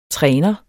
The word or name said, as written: træner